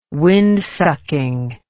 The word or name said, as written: windsucking